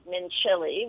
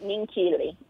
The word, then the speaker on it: Minchilli: Well, you were pronouncing it Mincilli, which mm-hmm. is how it's spelled, M-I-N-C-H-I-L-L-I. – Right. – But the C-H is actually hard, so it's Mincilli.